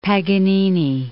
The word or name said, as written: Paganini